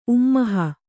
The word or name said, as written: ಉಮ್ಮೋಹ